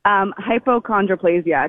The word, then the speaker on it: Hypochondroplasia: Hypochondroplasia. It's a type of dwarfism. – Okay. And what does that mean physically? – Honestly, to your body? – With hypochondroplasia, it basically just means I'm shorter than the average person.